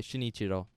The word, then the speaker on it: Shinichiro: Shinichiro.